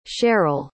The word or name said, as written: cheryl